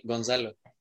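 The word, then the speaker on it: Gonçalo: David Fekir con 6 también y Goncalo Guedes, sí, no sé si lo dije bien. Eh, eh, creo que es Gonzalo. Gonzalo. Es que sí, como es portugués.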